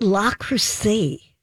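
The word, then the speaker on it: Le Creuset: But La Crusade, that is how you say it, isn't it? – Yeah.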